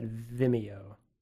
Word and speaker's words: Vimeo: I've always pronounced that Vimeo. Like video with an M instead of a D.